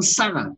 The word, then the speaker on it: Sarah: Und Sarah wird im Hebräischen ausgesprochen Sarah, mit Doppel-S. Und Huber schreibt das gerne mit Doppel-S, damit ihr wisst, es ist ein scharfes Sarah.